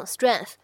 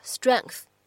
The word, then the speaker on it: strength: 很多同学呢在读的时候会读成 strength，没有问题。但是各位同学呢需要注意一下，在美式发音当中呢，这个单词也可以读成是 strength。 strength 这个单词呢，在美式发音当中可以有一个 k 的发音呢在其中，形成一个不完全爆破的处理。